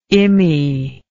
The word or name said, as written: immie